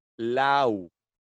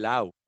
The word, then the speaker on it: Lowe: Se, ok, se deletrea L-O-W-E, pero se pronuncia Brendan Lau. – Lau. – Lau. No, Low. Se pronuncia Brennan Lau.